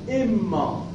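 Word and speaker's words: إمّا: با کسر میگن با کسر همزه و تشدید هم که میگن و تشدید میم، یعنی بگید اما. اما بالکسر و تشدید، اما با کسر همزه و تشدید میم، اما.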